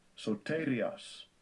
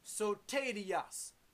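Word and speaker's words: Soterias (σωτηρίας): Strong's G, 4992, Soterias. – Soterias, 4992.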